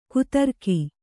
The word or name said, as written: ಕಾತರಿಕೆ